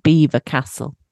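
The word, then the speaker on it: Belvoir Castle: We went to an event at, it's pronounced Beaver Castle. It's not spelt like that.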